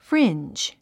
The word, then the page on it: fringe: fríndʒ; フリィンジ